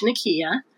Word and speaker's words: Nikeya: I'm on Instagram at Coach Nikeya. My name is spelled N-I-K-E-Y-A.